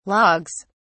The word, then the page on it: logs: British English